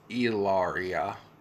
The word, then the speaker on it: Hilaria: Alec Baldwin explains why he and his wife, Hillary, although she spells it Hilaria and insists on it being pronounced Ilaria.